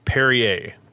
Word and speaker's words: Perrier: I don't know how much of that the mic got, but I'm drinking, I think it's pronounced Perrier. That's what my girlfriend tells me. Katie tells me it's pronounced Perrier, P-E-R-R-I-E-R.